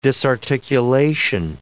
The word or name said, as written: disarticulation